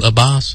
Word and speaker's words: Abbas: Now, he didn't present the plan because Abbas, how do you say that? – Abbas? – Yeah. – Mahmoud Abbas.